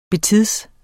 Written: betids

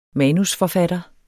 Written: manusforfatter

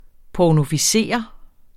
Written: pornoficere

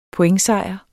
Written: pointsejr

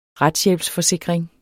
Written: retshjælpsforsikring

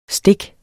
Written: stik

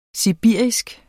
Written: sibirisk

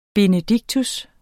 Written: benedictus

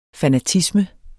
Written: fanatisme

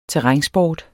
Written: terrænsport

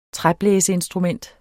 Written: træblæseinstrument

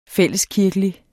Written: fælleskirkelig

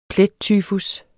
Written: PLETTYFUS

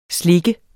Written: slik

